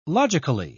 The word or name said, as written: logically